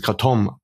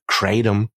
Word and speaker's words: Kratom: Und da neige ich doch eher dann mal wie dieses, hinten die Betonung zu lassen, also dieses Kratom anstatt Kratom, auch wenn das man auch im angelsächsischen Rahmen sehr viel hört.